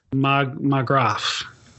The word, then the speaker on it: Marggraf: A guy called Andreas Sigismund Markgraf I don't really know how to say his name. Yeah. It's Andreas Sigismund Marggraf. Marggraf.